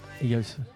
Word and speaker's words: Eagles: Qui a dit Eagles? – Ouais. – Toi, le point, toi, parce que t'as mieux prononcé.